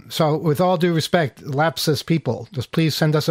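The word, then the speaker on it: Lapsus$: It's LAPSUS dollar sign.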